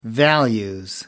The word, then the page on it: values: British English